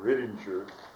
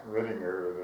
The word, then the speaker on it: Rittinger: I don't know whether I mentioned earlier that there was something that an Austrian named Riedinger, German Riedinger, the German would pronounce it, in English say Riedinger, R-I-T-T-I-N-G-E-R, said that the energy is proportional to the surface.